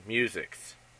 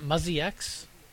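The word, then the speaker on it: Musyx: The answer is Musix. – Isn't it Muzzy X? – It's like Musy X or... – Well, it's M-U-S-Y-X is how it's spelled.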